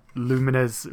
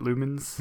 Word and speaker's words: Lumines: Then another one a lot of people are excited for, me included, Lumines Remastered. Now, I always pronounced it Lumens.